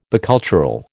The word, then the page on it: bicultural: American English